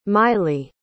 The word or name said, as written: mily